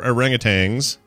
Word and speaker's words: orangutan: Well, yeah, but we also don't, there's no G at the end. So people who pronounce it orangutan, stop it, stop it.